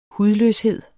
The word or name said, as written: hudløshed